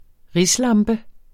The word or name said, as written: RISLAMPER